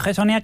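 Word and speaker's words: Hezonja: Mario Enzonja. – Sí, Mario Gessonia, que se salió con una actuación colosal en el Palacio. – Yo lo pronuncio a lo castellano.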